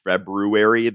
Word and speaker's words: February: This is February of 1964, and sorry, I'm one of those weirdos who insists on pronouncing February the way it's spelled.